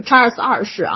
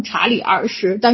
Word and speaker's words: 查尔斯二世: 那么当时的英格兰议会呢，一看苏格兰直接任命这查理，我这块也还是按照我的发音啊，就是查尔斯二世啊，就是在国内翻成查理二世，但是实际上按照英文发音应该是查尔斯二世啊，我习惯了说成查尔斯二世，就宣布查尔斯二世为这个不列颠和爱尔兰的国王。